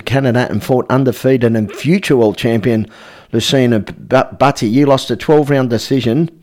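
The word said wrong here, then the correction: Lucian Bute: Lucien Butty.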